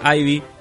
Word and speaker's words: Ivy: Dice que Ivy se pronuncia Ivy o en última instancia Eve.